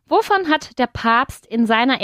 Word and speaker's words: Papst: Papst. – Papst, con una A alargada.